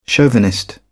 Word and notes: chauvinist: British English

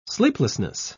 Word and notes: sleeplessness: British English

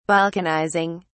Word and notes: balkanizing: British English

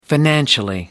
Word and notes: financially: American English

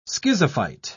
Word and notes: schizophyte: British English

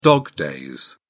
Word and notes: dog-days: British English

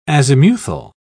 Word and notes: azimuthal: British English

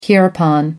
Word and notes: hereupon: American English

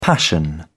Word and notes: passion: British English

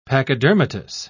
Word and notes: pachydermatous: British English

